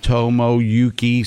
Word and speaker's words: Tomoyuki: Nolan, you have it down now, Tomoyuki? I could be butchering that now. – No, it's pretty phonetic. – Okay. – There's nothing weird there. – Tomoyuki Sagana.